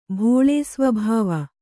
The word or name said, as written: ಭೋಳೇಸ್ವಭಾವ